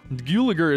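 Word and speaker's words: Gulager: Gulliger is the correct pronunciation according to the director of this, his son.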